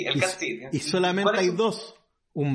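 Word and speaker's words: Cat Sith: En Irlanda, bueno, en Escocia también, pero en Irlanda igual, hay un gato que se le conoce como Cat Sid, que se escribe S-I-T-H. – Como Sid. Como Sid, como Lord como Sid.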